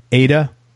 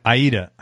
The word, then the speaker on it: Aida: In addition, Mike's played guitar for national tours of Broadway shows like Mamma Mia, Rent, Aida. Did I pronounce that right? – Aida.